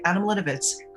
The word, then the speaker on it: Adam Litovitz: Your new album was written by yourself and your late partner and best friend, Adam Litovitz. Am I saying his name right? – Yeah, that's right. Adam Litovitz.